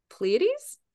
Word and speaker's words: Pleiades: One of the things that we talked about earlier is your connection to the Pleiades. I hope I'm saying that right. – Yeah, Pleiadians.